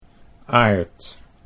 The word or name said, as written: Aard